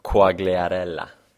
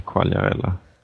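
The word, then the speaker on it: Quagliarella: Är det en Coagliarella? In disguise. – Precis. Nu uttalas ju det Coagliarella.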